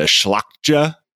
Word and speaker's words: Szlachta: He was the long-gone descendant of Polish-Lithuanian nobility, who were called the Szlachta, which, yes, I assure you, I pronounced that correctly.